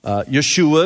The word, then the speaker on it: Yeshua: Yeshua is how you would pronounce it in Hebrew.